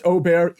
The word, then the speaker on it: Åberg: Well, it's actually pronounced Åberg.